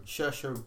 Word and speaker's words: Saoirse: I know it's Saoirse Ronan. Is that how you pronounce it? – Saoirse Ronan. – Saoirse Ronan.